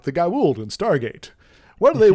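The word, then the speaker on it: Goa'uld: Gaould. There's an apostrophe. Yeah. – Yes. Gaould.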